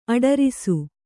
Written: ಅಡರಾಸು

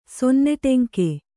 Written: ಸೊನ್ನೆಟೆಂಕೆ

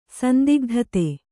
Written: ಸಂದಿಗ್ಧತೆ

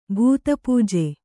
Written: ಭೂತಪೂಜೆ

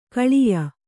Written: ಖಲ್ಯೆ

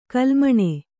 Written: ಕೋಲುಮೀನು

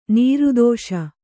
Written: ನಿರ್ದೆಸೆ

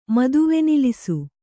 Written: ಮದುವೆನಿಲಿಸು

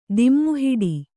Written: ದಿಮ್ಮುಹಿಡಿ

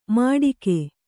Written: ಮಟಿಕೆ

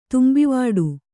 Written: ತುಂಬಿವಾಡು

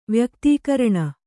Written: ವ್ಯಕ್ತೀಕರಣ

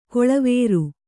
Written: ಕೊಳವೇರು

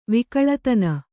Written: ವಿಕಳತನ

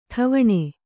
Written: ಟವಣೆ

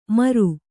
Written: ಮಾರು

